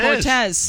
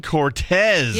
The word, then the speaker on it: Cortese: There it Cortez. – Is. Dan Cortez. – Yes. – Which is spelled Cortese, by the way.